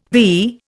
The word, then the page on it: vee: Indian